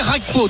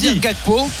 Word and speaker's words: Gakpo: Par exemple, non, je vais pas la jouer Rakpo, tu vois. Je vais jouer Gakpo.